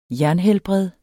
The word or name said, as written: jernhelbred